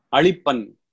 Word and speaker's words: அளிப்பன்: அளிப்பன் என்பது கொம்பளவிழியினா போட்டிருக்கிறபடியா அழித்தல் காத்தல். இதுல எங்களுக்கு ரொம்ப இதில் இந்த லீ உச்சரிப்பு மாறிச்சுன்னா வேற அர்த்தம் வந்துவிடும், அழித்தல் என்று வந்துவிடும்.